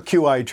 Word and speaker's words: Qi: And I'm glad you're using the term Qi, which is Qi, but it's Chinese pronounced Qi. A lot of people say, I have a Qi charger.